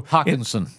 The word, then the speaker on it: Hockenson: They used the eighth pick in the draft on Iowa tight end TJ Hawkinstein, mm-hmm. uh, who's a blocker, who's also. Hockenson. Hockenson, I'm sorry.